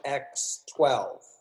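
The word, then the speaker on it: X Æ A-12: Elon Musk names his son, okay, this is what it is on paper, XAEX21 Musk, but it's actually pronounced X, and the AE is pronounced Ash, X-Ash X-12. – X-12. – Sorry, not 21, 12.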